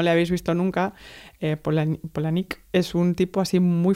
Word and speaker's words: Palahniuk: Nos pusieron en una entrevista conjunta con más gente todos a competir por hacerle pues preguntas a Chuck Palahniuk, que de hecho se pronuncia Polanik, pero bueno. – Ah, muy bien. – Polanik.